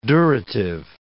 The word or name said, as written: durative